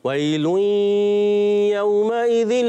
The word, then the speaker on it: ويل يومئذ: ويل إدغام کا غنہ بھی پورا کریں. ويل يومئذ للمكذبين.